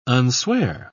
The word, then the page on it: unswear: British English